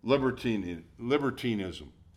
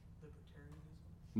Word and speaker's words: libertinism: Libertinism. How do you say that? Libertarianism. No. Libertinism. You got libertinism.